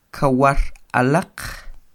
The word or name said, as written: kawar alaq